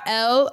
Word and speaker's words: Elle: So check out L Olivia. That's spelled E-L-L-E.